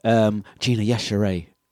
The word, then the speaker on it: Yashere: We're going to give away Gina Yashere. I've said it right. Yes, there we go.